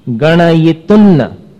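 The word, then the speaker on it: गणयितुं: अंग, अंग में जैसा उच्चारण है वैसे ही यहां पर नूनं गुणान गणयितुन्न। देखो तुन्न, तू के ऊपर जो अनुस्वार है उसका उच्चारण कैसा आया? न जैसा, क्योंकि आगे न है।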